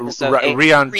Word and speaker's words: Rian: Oh, I'm talking about Rian Johnson.